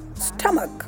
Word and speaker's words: stomach: That's stomach. Stomach. Not stomach. Not stomach or stomach. No, it's stomach. Stomach.